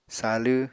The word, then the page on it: salw: apatani